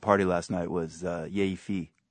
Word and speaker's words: fi: Sexy, I think it was kind of a joke, but the fi is actually pronounced in Portuguese fi, which is basically a kind of a slang, yay fi.